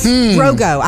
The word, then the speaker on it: Drogo: And the superhero, by the way, will be played by an actor named Jason Momoa, also known as Drago from Game of Thrones. Drogo. I said it wrong. Drogo.